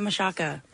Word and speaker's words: Machalka: CH is like an SH and the L is silent. So okay. I always tell people, think of Machaca like Chaka Khan.